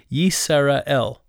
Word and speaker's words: Yisrael: So the Y on the front of it, Yisrael, is actually how it's said, Yisrael, and the Y is, sometimes people think it's the marker of a name.